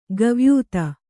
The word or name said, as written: ಕವಾಯಿತು